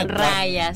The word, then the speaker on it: Raya: Raya, sí.